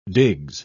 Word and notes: digs: British English

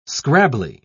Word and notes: scrabbly: British English

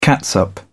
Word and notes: catsup: British English

